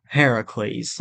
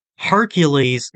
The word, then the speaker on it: Heracles: Or Heracles. – Right. – Is the Greek pronunciation. – Right. – Hercules is Roman, where Heracles is Greek.